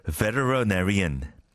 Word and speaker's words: veterinarian: Kind of a literary보다 한 몇 배 어려운 건데 veterinarian. Veterinarian. 수의사. Veterinarian. 정말 힘들죠. And again, if you pronounce it the British way, it's much easier. But it's those American pronunciation that treats T as L.